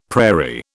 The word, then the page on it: prairie: British English